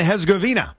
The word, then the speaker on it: Herzegovina: It's Bosnia and Herzegovina.